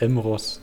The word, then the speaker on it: Mross: Also das ist übrigens Stefan Moros. Ähm, seit 2005 moderiert Moros. Mor das ist ein scheiß Name. Wie kann man den aussprechen? – Also er heißt M. – Ross, aber zusammengeschrieben. Mross. – Mross.